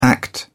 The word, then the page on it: act out: British English